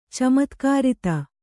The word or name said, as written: ಚಮತ್ಕಾರಿತ